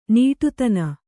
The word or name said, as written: ನೀಟುತನ